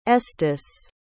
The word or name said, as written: estes